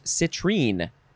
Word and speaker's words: citrine: Next is, well, it's spelled the same way, but it's pronounced citrine.